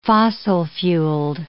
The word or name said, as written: fossil-fueled